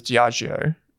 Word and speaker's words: Diageo: And I pronounce it as Diageo. I think that's how it's pronounced.